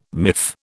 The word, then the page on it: miff: British English